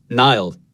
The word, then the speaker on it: Niall: When I was growing up, I would say Niall, like N-I-L-E effectively, like the river. And since I came to America, people couldn't understand what I was saying, so I broke it into two syllables.